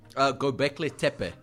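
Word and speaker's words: Göbekli Tepe: Gobekli Tepe.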